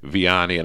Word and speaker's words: Vianney: on Wednesday, May 27, 2026, at St. John Vianney, and I'm going to spell that, capital V-I-A-N-N-E-Y.